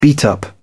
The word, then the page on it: beat up: British English